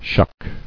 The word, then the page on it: Shucker: shuck